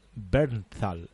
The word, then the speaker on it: Bernthal: y en, e-, este caso hablamos de John Bernal. ¿Cómo se pronuncia? John Bernthal. Bernthal, ¿no? Sí.